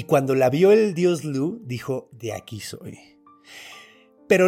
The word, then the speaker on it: Lug: Básicamente, es hijo del dios Lu, que se escribe Lug.